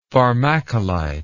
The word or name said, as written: pharmacolite